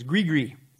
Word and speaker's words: gris-gris: Now, in Louisiana voodoo, they're also big on amulets, and they will use these things called, you can pronounce it gris-gris, or the correct pronunciation is gris-gris.